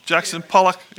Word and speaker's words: Jackson Pollock: Am I saying that right, Tom? Jackson Pollock? Okay.